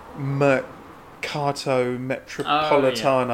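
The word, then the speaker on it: Mercato Metropolitano: We did, I think, what is now I won't pronounce it right, Mercato Metropolitano. Oh, yeah. In my that's perfect Italian there for anyone listening in Elephant Castle that was Kerb did a load of events.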